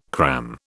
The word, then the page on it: cram: British English